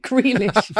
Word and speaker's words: Grealish: åt andra hållet och sen så Dremo Gril, eh, Grilish, gud vilket skånskt uttal, Grealish.